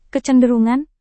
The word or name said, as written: kecenderungan